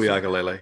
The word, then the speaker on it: Uiagalelei: DJ Uiagalelei. Uiagalelei, is that how you pronounce Uiagalelei. – It? Uiagalelei. Man.